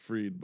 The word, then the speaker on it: Fried: I would pronounce it Freed, but it's spelled Fried. – Yeah, F-R-I-E-D, Fried.